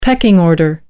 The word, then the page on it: pecking order: American English